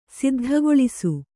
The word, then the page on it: ಸಿದ್ಧಗೊಳಿಸು: Kannada